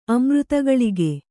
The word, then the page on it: ಅಮೃತಗಳಿಗೆ: kannada